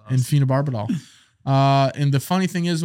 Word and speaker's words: phenobarbital: Phenobarbital.